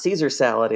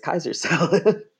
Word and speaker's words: Caesar salad: So it's not Caesar salad, it's Kaiser salad.